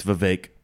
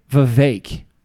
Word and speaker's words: Vivek: And for the record, it's Vivek like cake. – I thought it was Vivek. – No, it's Vivek. – Vivek.